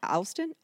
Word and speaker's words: Allston: Alston? – A-L-L-S-T-O-N.